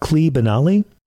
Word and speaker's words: Klee Benally: I want to ask you guys about Klee Benali. Am I saying his name correctly? – Yep.